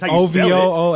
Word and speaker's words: Uovo: It's U O V O. – Yeah. – So how you say? – Oh, Uovo. – Uovo. – No. – It's just Ovo? The U is silent? – Yeah, the U is silent. – Oh, so just Ovo.